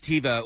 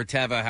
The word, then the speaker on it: Teva: Those are well, that brand is Teva or Teva, however you want to pronounce it.